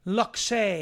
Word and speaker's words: luxe: The UN COP28 climate summit features an online portal showing all the looks, the looks, luxe, L-U-X-E. How do you pronounce that word? Lux. Food. Why, why, then why is there an E? L-U-X would be lux in and of itself.